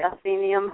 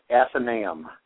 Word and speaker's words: Athenaeum: Is it pronounced the Athenaeum Hotel? – You're almost correct. It's actually pronounced Athenaeum. It is a Greek, it's derived from the Greek term for house of learning.